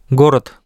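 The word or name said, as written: го́род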